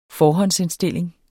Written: forhåndsindstilling

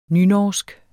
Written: nynorsk

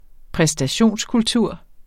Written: præstationskultur

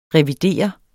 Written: revidere

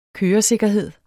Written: køresikkerhed

